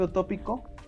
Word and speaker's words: utópico: Escape utópico lleva acento en la O, no en la I.